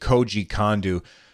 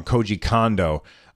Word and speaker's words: Koji Kondo: And the music, the amazing Zelda music, was created by the great Koji Kondo. I said his name wrong. Koji Kondo.